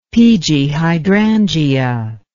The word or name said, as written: peegee hydrangea